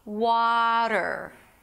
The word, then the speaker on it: water: Like, think of it as a double A vowel, as if it's spelled with two A's, wah. Then you have the flap T, okay? So in America, you won't hear water, although there is a T there and you're used to pronouncing T as T, okay? It actually sounds like a D, water.